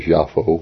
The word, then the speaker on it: Yapho: Now Joppa, pronounced in the Hebrew as Yapho, Y-A-P-H-O, means beauty.